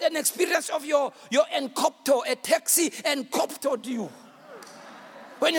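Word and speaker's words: enkoptō: That word hindered in Greek it's spelled E G K O P T O. E G K O P T O. But it's pronounced encopto.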